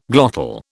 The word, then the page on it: glottal: British English